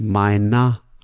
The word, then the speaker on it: meiner: Notice the pronunciation. The er at the end sounds like a. So it sounds like mit meiner Tochter.